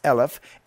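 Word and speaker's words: eleph (אלף): It's still an aleph, a lamed, and a pei, those three letters pronounced eleph, and it's translated as a thousand.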